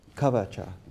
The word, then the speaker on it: kavacha: Another story that brings out that point was one time Govinda Maharaj was bathing in the Ganga, and he had a kavacha. Is that how you pronounce it? Kavacha.